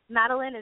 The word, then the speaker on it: Madelyn: Madeline is M-A-D-E-L-Y-N.